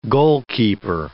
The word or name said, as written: goalkeeper